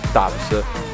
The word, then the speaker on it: DVBBS: Si pronuncia Dubs.